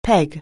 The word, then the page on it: peg: anglais